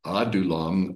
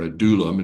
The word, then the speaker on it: Adullam: The word Adullam or Adullam, it depends on where you want to put the emphasis on which syllable.